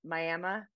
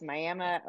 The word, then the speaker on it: Miami: Miami. – It's spelled like Miami, but it's Miami, Oklahoma. So do you remember sort of where that idea started?